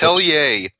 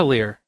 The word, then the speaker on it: Atelier: Atelier. Pronounce it right. – Atelier. – It's Adelir. – Atelier is how you pronounce it. It's the only way to pronounce it.